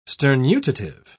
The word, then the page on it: sternutative: British English